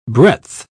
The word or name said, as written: breadth